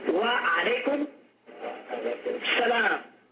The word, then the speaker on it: Wa alaykum salam: Wa alaykum salam. As-salamu alaykum. Wa alaykum salam.